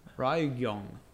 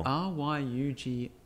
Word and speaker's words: Ryugyong: Rugyong? Is that how you would pronounce it? – Ryugyong. Yeah, hotel. – Ryugyong.